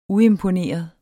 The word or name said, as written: uimponeret